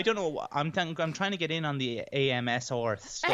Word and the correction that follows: ASMR: ASMR. You need to get the name right first. ASMR. – ASMR, is it?